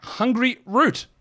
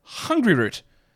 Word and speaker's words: Hungryroot: But first, I'm going to tell you about Hungry Root. Actually, it's not pronounced that way. It's pronounced Hungry Root.